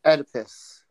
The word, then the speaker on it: Oedipus: Yes, yeah, I know the Oedipus? – Oedipus complex.